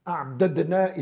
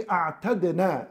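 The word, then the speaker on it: أعتدنا: A'dadna is heavy. So Allah Azza wa Jal would say a'tadna, and this is known in the Arabic language, takhfifan, to make the language and the pronunciation of the language lighter and easier on the tongues of mankind.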